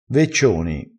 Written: veccioni

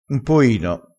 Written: un poino

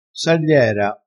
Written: sagliera

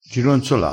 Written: gironzola

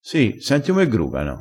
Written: gruga

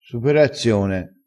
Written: superazione

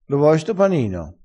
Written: friggersi

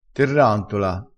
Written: terrantola

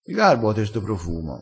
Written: garba

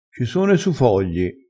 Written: su

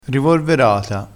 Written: rivorverata

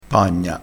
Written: pagna

